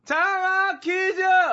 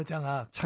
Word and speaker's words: 장학: 발음 정확히 다시요. – 장학 퀴즈! – 장학 어, 장악. 장악은 아닙니다. 장악은 뭘 마음대로 휘두를 때 장악했다 뭐 그러잖아요.